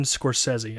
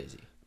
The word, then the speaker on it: Scorsese: And it's Martin Scorsese. – That's how you say it. Martin Scorsese. – Martin Scorsese is how he says his name, so I'm going to roll with that.